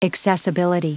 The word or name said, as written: accessibility